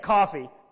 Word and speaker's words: Caughey: You spell it C-A-U-G-H-E-Y, but you say it Coffey.